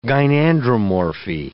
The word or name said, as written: gynandromorphy